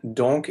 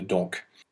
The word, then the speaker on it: donc: However, if it's in front of a word that starts with a consonant, then the K will be silent. Viens donc sur ce banc de mousse. And also, if it's the last word of the clause, then it will also not be pronounced. We will not have donc at the end of a sentence. It will always be don.